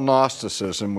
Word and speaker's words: Gnosticism: century, and that was the movement called Gnosticism. Now, when we spell Gnosticism, we spell it with a G, G-N-O-S-T-I-C-I-S-M. And Gnosticism comes from the Greek word gnosis, which is also spelled with a silent G, G-N-O-S-I-S, which is simply the Greek word for knowledge.